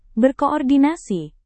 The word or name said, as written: berkoordinasi